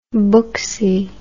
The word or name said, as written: booksy